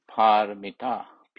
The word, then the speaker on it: Paramita: Parami pronounced P A, which is long A, R A M I, Parami, and O Paramita, P A, A long A, R A M I T A, long A, Paramita.